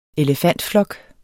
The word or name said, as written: elefantflok